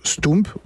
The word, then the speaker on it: stoemp: Alors stoump, ça s'écrit S-T-O-E-M-P, et ça se prononce stoump, pourquoi? Parce que O-E... En Belgique, en flamand, ça se prononce ou comme Pouls, comme Poulvorde, comme un certain nombre d'autres exemples.